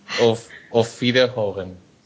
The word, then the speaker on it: Auf Wiederhören: Auf Wiederhören.